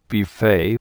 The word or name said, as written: buffet